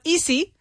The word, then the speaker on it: easy: It's not easy. Easy me voy a. It's not that. It's easy. Easy with a z sound, like Brazilian or Brazil.